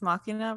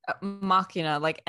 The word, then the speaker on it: Machina: Machina, I think I'm saying it right. M-A-C-H-I-N-A, like machine.